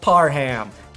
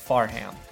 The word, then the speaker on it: Parham: Donald Parham, now pronounced correctly. Imagine loving a player so much that you say his name wrong for 2 damn weeks. That's how much I love Donald Parham, formerly Farham.